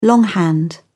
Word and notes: longhand: British English

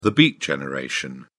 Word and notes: beat generation: British English

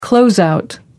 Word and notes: closeout: American English

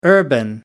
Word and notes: urban: American English

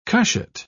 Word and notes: cushat: British English

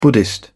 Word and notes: buddhist: British English

